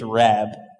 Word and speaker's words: rab: And the Hebrew word for multitude in Exodus 12:38 is rab. Probably not pronouncing that wrong. R-A-B, rab.